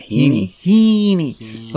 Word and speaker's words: Heaney: Heaney. Like Mr. Feeney?